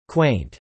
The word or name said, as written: quaint